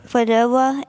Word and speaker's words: Qdoba: Well, I really like Mexican right now, like qdoba and stuff like that.